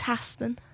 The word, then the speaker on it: Haston: It's Haston. – Haston. – Yeah, but some people say like Haston.